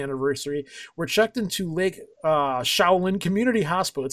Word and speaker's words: Chelan: Chelan, is it Chelan? C-H-E-L-A-N. I'm going to pronounce this. – Shaolin. – Shaolin.